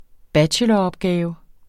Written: bacheloropgave

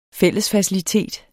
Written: fællesfacilitet

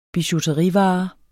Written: bijouterivarer

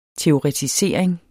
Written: teoretisering

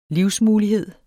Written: livsmulighed

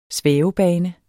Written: svævebane

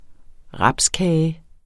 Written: rapskage

